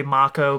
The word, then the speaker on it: Mako: Like, so Avalanche, their whole thing is they're destroying I will say Mako forever, but they say Mako because they're using the proper Japanese pronunciation.